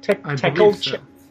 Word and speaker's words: Tequilcello: It's Tequilcello. Am I pronouncing that correctly? Tequilcello? – Tequilcello. You drop Tequicello. The A at the end of tequila. – Yes.